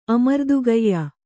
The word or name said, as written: ಅಮರ್ದುಗೆಯ್ಯ